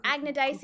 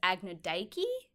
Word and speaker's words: Agnodice: Is it A G N O D I C E? Correct. And sometimes in some texts, she is known as Agnodike.